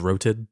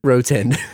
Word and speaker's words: Roten: Robert Rotten from the Laramie Movie Scope. – I believe it's pronounced Roten. – Roten.